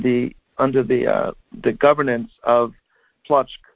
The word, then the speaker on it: Płock: But the original name of the city is P-L-O-C-K, and it's pronounced Płock.